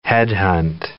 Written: headhunt

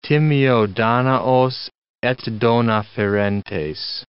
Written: timeo Danaos et dona ferentes

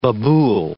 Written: babul

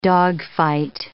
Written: dogfight

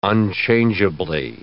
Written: unchangeably

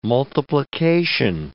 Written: multiplication

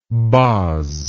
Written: boz

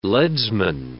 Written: leadsman

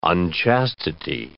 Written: unchastity

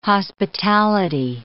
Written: hospitality

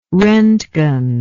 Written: rontgen

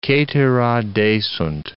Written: cetera desunt

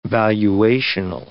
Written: valuational

